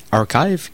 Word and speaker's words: arXiv: La plus connue, c'est Archive, qui s'appelle ARXIV. Je pense que c'est une joke par rapport à une lettre grecque. C'est pour ça que ça se prononce Archive en anglais, ce qui veut dire l'archive.